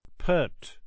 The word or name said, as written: pert